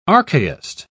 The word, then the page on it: archaist: British English